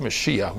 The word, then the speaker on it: Mashiach: Peter's answer was the Messiah, which in the Hebrew is pronounced Mashiach, which means chosen one or anointed one.